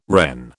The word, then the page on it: Wren: British English